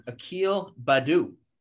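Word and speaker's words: Akil Baddoo: One more guy to buy or sell on, Akil Badu.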